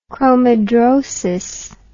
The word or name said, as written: chromidrosis